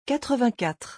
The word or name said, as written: 84